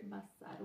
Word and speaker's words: bâsâr: mas com S, e o S em hebraico tem som de S, não de Z, Bassar, né? Ok? Bassar, um S só. Bassar, com um R só no final. Bassar.